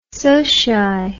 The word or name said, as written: socii